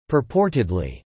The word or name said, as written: purportedly